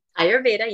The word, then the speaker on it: Ayurveda: Ayurveda, yep.